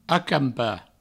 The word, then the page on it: acampar: acampa